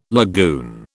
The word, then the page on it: lagoon: British English